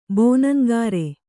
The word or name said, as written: ಬೋನಂಗಾರೆ